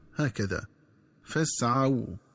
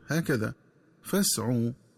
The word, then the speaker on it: فاسعوا: وكذلك ينبغي التنبه للنطق الصحيح للفظ فاسعوا، فيقرأ بفتح العين وبعدها واو لينة هكذا فاسعوا، ولا يقرأ بضم العين وبعدها واو مدية هكذا فاسعوا.